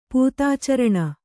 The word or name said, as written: ಪೂತಾಚರಣ